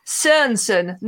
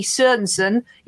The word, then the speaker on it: Sørensen: It's Sørensen, not Sorensen, because, again, he's Danish. And Sørensen is spelt with a line through the O, it would be like spelling Maddie, M-E-D-D-I-E, and pronouncing it Meddy. It's not the same letter. It's not just you've forgotten the line. It makes it a different letter. So it's Jakob Lundi Sørensen. You sort of don't pronounce the O. You don't pronounce the R as hard.